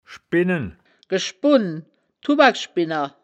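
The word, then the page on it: schbinnen: Wittlicher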